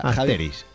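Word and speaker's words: Astérix: Yo sé que es Astegui y Obelí. O una cosa parecida. – Asteris. Javi, Asteris. Javi sabe, se ha llamado de toda la vida Asteris, ese señor.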